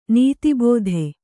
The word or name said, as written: ನೀತಿಬೋಧೆ